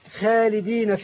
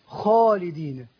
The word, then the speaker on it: خالدين: مثلا البعض ينطق كلمة خالدين فيها. – نعم. – خالدا. – نعم. – هذا نطق خطأ. – نعم. – النطق الصحيح خالدين.